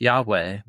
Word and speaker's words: Yahweh: with a being whose name is pronounced Yahweh, but what it means is the one who is.